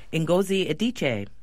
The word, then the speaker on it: Ngozi Adichie: Ngozi Adichie.